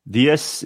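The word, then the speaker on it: Diess: DS is pronounced D's. Is that how you pronounce D's? D-E-E-Z.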